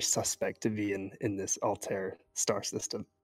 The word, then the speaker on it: Altair: Yeah, I figured there'd be a lot of Assassin's Creed fans listening too, and they'll know that word as Altair. But my understanding is that the star is pronounced Altair.